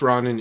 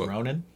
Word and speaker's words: Ronin: The Last Ronin is a cool. – I believe it's pronounced Ronin. – Ronin. Sorry. My bad.